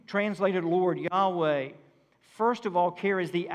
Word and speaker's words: Yahweh: The exact pronunciation of God's name in the Old Testament was lost in antiquity because the Jews considered it too sacred to pronounce. So we believe it was probably pronounced Yahweh.